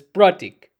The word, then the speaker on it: Prateek: If you were to ask me how to pronounce my name, my real name of how to pronounce it is Pratik. Okay, but if you ask me how what my name is, I'll be like Pratik.